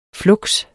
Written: fluks

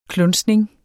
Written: klunsning

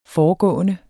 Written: foregående

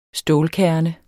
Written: stålkerne

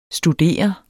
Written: studere

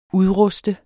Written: udruste

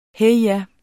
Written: heja

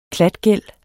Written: klatgæld

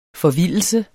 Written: FORVILDELSERNES

